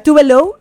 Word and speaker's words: Tove Lo: O sea, se llama Tove Lo, o sea, Tove Lo, es la pronunciación, pero su nombre real es Eva Tove Elsa Nilsson.